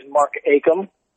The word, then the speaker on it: Mark Acomb: The correct name, the correct way to say it is Mark Acomb. – Acomb. – The B is silent.